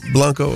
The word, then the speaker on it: Winter Blanco: And in this situation with Winter Blanco, am I saying her name right? – Yes. – Blanco? Okay, I just want to make sure.